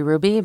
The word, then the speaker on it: Hruby: So my handle is at Aubrey Ruby, but my last name is spelled H-R-U-B-Y.